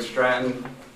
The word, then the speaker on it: Stratton: My name is Craig Stratton. My last name is spelled S-T-R-A-T-T-O-N.